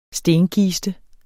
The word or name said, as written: stenkiste